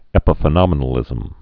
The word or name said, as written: epiphenomenalism